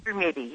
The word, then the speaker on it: Brumidi: Brumidi? – Brumidi, yeah.